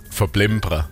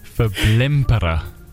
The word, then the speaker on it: Verblimpere: Ja, was ganz altes Schwäbisches. Verblimpere. – Verblimpere. Ich habe Kinder. – Du hast gut ausgesprochen.